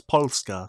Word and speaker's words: Polska: It's Polska. So, yeah, still two syllables.